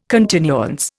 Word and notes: continuance: Indian

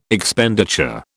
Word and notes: Expenditure: British English

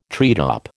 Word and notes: treetop: American English

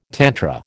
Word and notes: tantra: American English

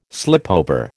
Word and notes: slipover: American English

